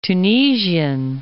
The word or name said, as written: Tunisian